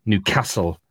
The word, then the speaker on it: Newcastle: and he taught me how to pronounce Newcastle properly. And Newcastle. We got talking Newcastle. You announce the cass bit, not the car bit like we do down here. But